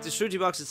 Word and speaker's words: shruti: Yeah, shruti box. – So how do you spell it? – S H R U T T I. – Right, shruti. – Yeah. – Shruti. As in tutti frutti shruti.